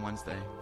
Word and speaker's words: Wednesday: A little bit of food for thought, just like how you don't pronounce the D in Wednesday. – Wednesday. Well, never thought of it like that. – Not the D, but like, it's spelled differently than it's pronounced. You spell it Wednesday. You don't say it.